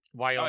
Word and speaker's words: Wyoming: Now I always say this wrong because I say it with an Australian accent. But Wyoming. How do you say it, in America? – Wyoming. – Oh, I said it right. Good.